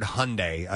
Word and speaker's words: Hyundai: Which, by the way, if you ever say the word Hyundai, I've been told by the corporate directive is Hyundai rhymes with Sunday.